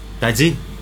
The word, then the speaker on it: Tallín: ¿Cómo se pronuncia? – Tallín. – Tallín. ¿Así? Sí.